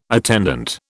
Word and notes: attendant: British English

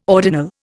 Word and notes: ordinal: Indian

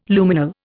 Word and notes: Luminal: Indian

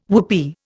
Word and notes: whoopee: Indian

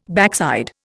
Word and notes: backside: Indian

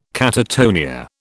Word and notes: catatonia: British English

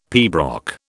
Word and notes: pibroch: British English

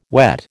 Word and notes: wet: American English

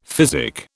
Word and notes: physic: British English

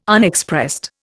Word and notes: Unexpressed: Indian

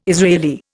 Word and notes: Israeli: Indian

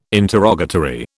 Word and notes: interrogatory: British English

